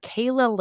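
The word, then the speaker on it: Calah: And then we have Kayla Lane.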